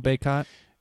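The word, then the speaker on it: Bacot: But how do you say the Baycott, right? – Yeah, Armando Baycott.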